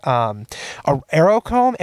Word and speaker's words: Aerochrome: I developed a preset which would make it's actually inspired by Kodak Aerochrome. Aerochrome is how you pronounce it.